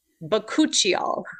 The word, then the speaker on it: Bakuchiol: Bakuchiol.